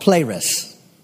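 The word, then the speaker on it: pleres (πλήρης): Now, this word full here is a Greek word called pleres. That's how we would pronounce it.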